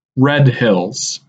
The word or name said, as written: Red Hills